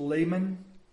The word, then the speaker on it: Lehman: Frederick, I believe his name is pronounced Lehman.